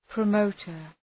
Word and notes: promoter: prə’məʋtər

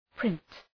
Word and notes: print: prınt

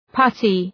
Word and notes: putty: ‘pʌtı

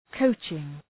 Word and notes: coaching: 'kəʋtʃıŋ

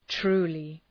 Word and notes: truly: ‘tru:lı

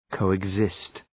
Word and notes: coexist: ,kəʋıg’zıst

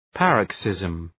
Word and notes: paroxysm: {‘pærək,sızm}

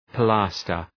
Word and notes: pilaster: pı’læstər

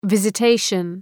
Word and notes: visitation: ,vızə’teıʃən

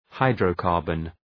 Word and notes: hydrocarbon: {,haıdrəʋ’kɑ:rbən}

